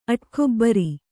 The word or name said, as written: ಅಡಕೆಬೇರು